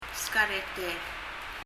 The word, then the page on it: skareter: [skarɛtɛ(:r)]